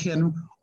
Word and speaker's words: Henri: People like Henri, if I'm using the French pronunciation,